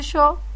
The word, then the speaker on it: Shaw: Recuerden que el apellido Yo es SHAW, se dice Yo, pero se escribe con A.